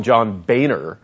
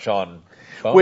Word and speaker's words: Boehner: It's, a lot of people call him John Boehner. I've actually seen video where he has corrected people and said it's pronounced Boehner.